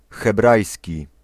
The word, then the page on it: hebrajski: /xɛˈbrajs̪kʲi/